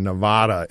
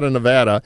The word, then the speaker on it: Nevada: I've been saying Nevada, and I keep getting mail, but for some reason I'm stuck on the wrong pronunciation. So Nevada, and I knew that. It's one of these things that just kind of stuck in my head.